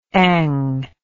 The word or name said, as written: eng